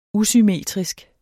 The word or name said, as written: usymmetrisk